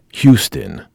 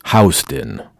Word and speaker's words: Houston: For those who are unfamiliar with New York, in Texas, it's pronounced Houston. In New York, it's pronounced Houston.